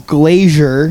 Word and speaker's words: Glaisyer: Brock's last name is Glazier. – Got it. You said it right too.